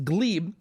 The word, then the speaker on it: Gleb: His name is Glebe, spelled Gleb, but pronounced Glebe,